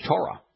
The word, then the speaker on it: Torah: A wonderful example happens to be Torah. Torah is T-W-R-H, and the O in Torah comes from the Hebrew wa. The ah in Torah, that vowel sound, comes from the Hebrew he.